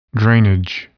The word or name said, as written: drainage